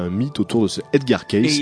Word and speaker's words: Edgar Cayce: ça s'écrit C-A-Y-C-E, Edgar Cayce, et vous verrez que le personnage est encore très vivant dans l'esprit de beaucoup de personnes, même s'il est mort en 1945.